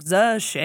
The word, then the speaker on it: Zexi: And even when we were shown his name, his name was Z-E-X-I. So in our English, we would say Zexi. And she's like, no, it's correctly pronounced. I'm still going to butcher it, but like Z-she. And because Chinese is tonal.